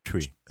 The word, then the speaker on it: Twi: T-W-I. – T-W-I. Yeah, Tree. – Twi. – Like Tree. Like C-H. You pronounce it almost like a C-H-W-I.